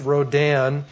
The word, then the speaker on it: Rodin: I guess it's pronounced Rodin.